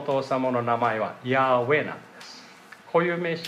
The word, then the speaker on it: ヤーウェ: で、これヘブライ語でヤーウェと言います。ヤーウェと発音します。